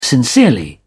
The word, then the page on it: sincerely: British English